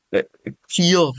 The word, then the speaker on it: Keir: Wie spricht man es aus? – Keir, würde ich sagen. Ja. Also es ist, er ist zwar Brite, aber es klingt irgendwie keltisch, schottisch, was auch immer.